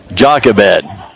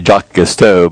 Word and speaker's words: Jochebed: Her name was Jochebed. I keep want to say Jocasto, but Jochebed is a mama that God brought to my heart to share this morning.